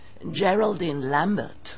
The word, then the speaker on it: Geraldine Lambert: Wird aber eigentlich Englisch ausgesprochen. Geraldine Lambert.